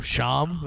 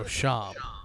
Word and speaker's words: Shyam: So Shyam, that's what it is. So the Y is silent. Yeah. There's no Y.